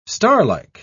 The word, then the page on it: starlike: British English